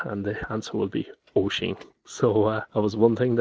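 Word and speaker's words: Oisín: I didn't know what name it was, and I downloaded the PDF of the book to find out that the spelling was O-I-S-I for the N, and that is pronounced O-Sheen. It's not pronounced O-Sin, it's O-Sheen, O-Sheen.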